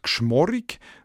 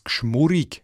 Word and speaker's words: geschmorrig: Genau so ist es beim Wort «geschmorrig», wo in den anderen Deutschschweizer Dialekten darum eben als «geschmurrig» ausgesprochen wird.